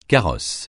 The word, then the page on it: carrosse: francais